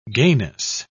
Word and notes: gayness: British English

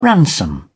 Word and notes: ransom: British English

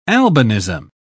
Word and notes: albinism: British English

